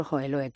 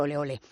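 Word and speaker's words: Hohenlohe: Pues eso, quien fue Marbella, y quien lo ideó, fue el príncipe Alfonso Joeloe, que le llamaban, como, bueno, pues no pronunciaban su nombre, decían Alfonso Oleole.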